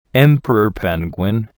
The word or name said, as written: emperor penguin